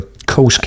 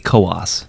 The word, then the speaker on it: Coos: Is it Coos County or Coast County? – I believe it's Coos. – Coos County, yeah.